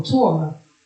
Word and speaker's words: Torah: Same word I just spelled out. – Spell it again. – T-O-R-A-H. – Oh, Torah.